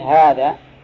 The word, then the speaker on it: هذا: ابن الحارث، لكن هم مثل الحارث ما يحطون فيها ألف، هذا ينطق في الألف ولا تكتب، مثل هذا أي هذا فيها ألف بين الها والذال، نطقاً فيها ألف، لكن كتابة لا.